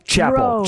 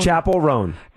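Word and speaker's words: Chappell Roan: I've heard a lot of artists talk about Chaperone. That's a good way of describing her. – And how do we say her name? – Chaperone. – Chaperone.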